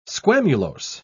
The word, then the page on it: squamulose: British English